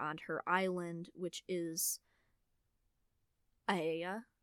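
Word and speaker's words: Aeaea: It's literally spelled it's spelled A-E-A-E-A. I'm just like, what in the world? I couldn't figure out the pronunciation without looking it up. So that's how you pronounce it, if you're wondering.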